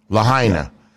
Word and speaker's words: Lahaina: and they discussed turning Hawaii and Lahaina, or help me say that right, please. – Lahaina. – Yeah. Lahaina and that area into one of the first 15-minute cities, and then Hawaii becoming kind of an incubator for this technology.